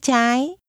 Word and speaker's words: trái: Sau đó, anh phải rẽ trái. Trái. Trái. Rẽ trái. – Left is pronounced with a high rising tone.